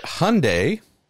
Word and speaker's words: Hyundai: Would say, though, James, and thanks for the insult about my mother, Hyundai, as they tell us to pronounce it, Hyundai like Sunday,